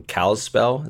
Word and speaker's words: Kalispell: We have some family down in Kalispell.